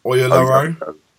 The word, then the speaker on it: Oyelowo: Oyelowo.